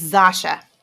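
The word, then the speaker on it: Zosia: Okay, it's like Sasha, but with a Z. So it's Sasha Mamet. – Is that really how you say it? Just Sasha? – No clue. – It's Zasha.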